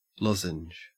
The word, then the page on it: lozenge: Australian English